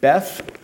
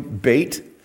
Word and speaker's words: Beth: So let's read Psalm 119, verses 9 through 16, section Beth. Or if you want to pronounce it with modern Hebrew, Beit, but is verses 9 through 16 in Psalm 119.